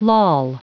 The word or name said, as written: loll